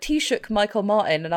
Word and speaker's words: Taoiseach: it's easy to pronounce once you know. It's just pronounced Taoiseach.